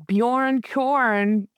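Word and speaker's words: Bjorn Qorn: Our next up is Bjorn Korn. I guess it's corn. It's spelled with that fun Q.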